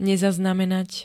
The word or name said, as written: nezaznamenat